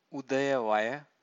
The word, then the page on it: udayavaya: Pali